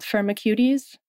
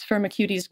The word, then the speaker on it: Firmicutes: So this pill from Ceres Therapeutics is based on the idea of narrowing down the microbes to bacteria in this phylum called Firmicutes. I've heard it pronounced, I think, 3 different ways, but I prefer the cuties pronunciation. – I'll take cuties for this.